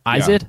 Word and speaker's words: Izod: That's that underwear brand that I-Z-O-D? – They sell. Yeah, at Walmart. Izod? – Yeah, Izod.